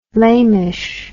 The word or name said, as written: lamish